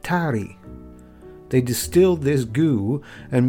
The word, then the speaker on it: tari: Toddy is from the Hindi word, tari, spelled T-A-R-I, but pronounced with the R sound approaching an English D.